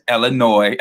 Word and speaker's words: Illinois: Not with the, you don't pronunciate the S, so just Illinois.